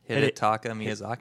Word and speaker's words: Hidetaka Miyazaki: Hidetaka Miyazaki.